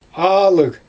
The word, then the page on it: aalyg: Xyzyl